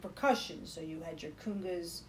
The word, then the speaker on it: conga: I like the way you say kungas. – Why am I saying it wrong? – No, you say it right. I taught you the right way. Huh. I'm impressed. All right, continue. – You taught me. – Yeah. An Italian from Scarsdale is going to say congas.